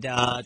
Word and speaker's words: Tua: We're going to talk a little bit about the Tua Tagovailoa situation. And Tua, a good job on that.